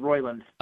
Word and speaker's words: Roiland: For those of you that are fans of Rick and Morty, it came out this week that Justin Roiland, is that how you say his name? Roiland? – I think so. I think it's Roiland.